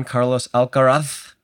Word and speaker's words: Alcaraz: So might see world number one Carlos Alcaraz, which is how you say it in Spanish.